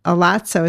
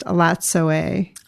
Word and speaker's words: Elatsoe: What she talks about, Elatso, it's actually pronounced Elatsoe.